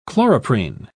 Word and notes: chloroprene: British English

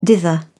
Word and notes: dither: British English

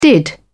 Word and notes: did: British English

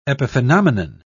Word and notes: epiphenomenon: British English

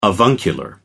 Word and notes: avuncular: American English

